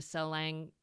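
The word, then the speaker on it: Salang: I wonder if you might just want to describe this, I don't know if I'm going to say it right, but the Selang Tunnel? – Perfect, yes. So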